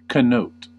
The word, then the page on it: bedeuten: /bəˈdɔɪ̯tn̩/; /bəˈdɔɪ̯tən/